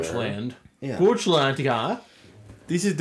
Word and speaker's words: Goochland: It sits undisturbed on a bluff overlooking the James River, about 15 miles west of Richmond, in Goochland County. – It's Goochland. – Yeah. – It's pronounced Goochland.